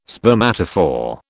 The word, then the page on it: spermatophore: British English